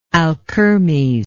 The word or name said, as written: alkermes